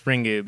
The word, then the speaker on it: Ringu: No, but yeah, so it's the word ring, but it's Ringu because it's how you would pronounce it after writing it in katakana.